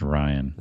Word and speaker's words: Rian: I guess it's Ryan. – Ryan. Spell it right then.